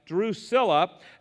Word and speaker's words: Drusilla: Well, Felix came with his wife, Drusilla, would be the proper pronunciation, who was Jewish.